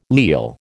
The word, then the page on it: leal: American English